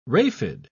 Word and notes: raphide: British English

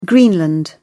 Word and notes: greenland: British English

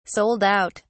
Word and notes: sold out: British English

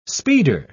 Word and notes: speeder: British English

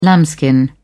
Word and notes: lambskin: British English

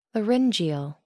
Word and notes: laryngeal: American English

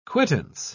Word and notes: quittance: British English

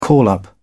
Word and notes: call up: British English

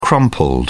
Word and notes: crumpled: British English

